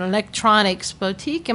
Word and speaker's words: Electronics Boutique: And I didn't, like I said, I didn't grow up in this area, but Tony worked at an electronics boutique.